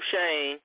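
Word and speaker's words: Schoen: It is Joe Shane. And I know, and that's how we got to pronounce it, because he is our general manager now. We got to get his name right.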